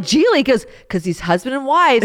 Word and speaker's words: Gigli: And well, it was Gigli. The other G is silent.